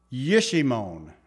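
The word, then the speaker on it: Jeshimon: That's how you say it, in the plain of, on the south of, Now, this is literally Yishimon, is how you say it in the Hebrew, Yishimon.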